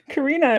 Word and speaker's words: Karina: Karina, it rhymes with Corona.